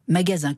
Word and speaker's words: magasin: Alors, logique, me direz-vous, puisque ça se prononce magasin. Oui, enfin, quand même, en français, jusqu'à preuve du contraire, le plus souvent, un S entre deux voyelles se prononce Z.